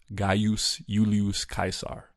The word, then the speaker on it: Gaius Julius Caesar: At the time, it would have actually been pronounced Gaius Julius Caesar.